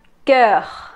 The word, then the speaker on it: cœur: Se escribe O, E, pero esas van pegadas, como si fuera una sola, es una O con una E pegadas, y una U. Eso hace el sonido cœur, cœur.